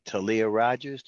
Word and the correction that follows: Tally Rogers: Did I say it right? – No, Tally Rogers.